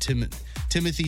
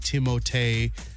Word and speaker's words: Timothée: We've talked about Timothy Chalamet should actually be Timothée Chalamet, but we're not doing that.